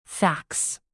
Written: fax

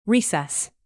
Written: recess

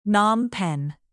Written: phnom penh

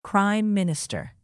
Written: prime minister

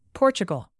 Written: portugal

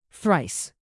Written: thrice

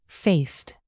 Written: faced

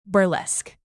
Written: burlesque

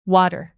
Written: water

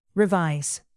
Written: revise

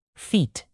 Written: feat

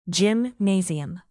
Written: gymnasium

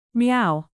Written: meow

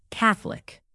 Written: catholic

